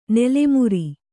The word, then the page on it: ನೆಲೆಮುರಿ: nele muri